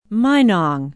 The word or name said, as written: minong